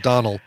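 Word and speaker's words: Domhnall: Well, but I think it's pronounced Donald, but it's definitely pronounced Donald, but it's spelled Domnall.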